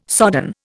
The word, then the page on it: sodden: Indian